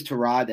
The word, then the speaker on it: Tyrod: His real name is Tyrod. That's how it's pronounced.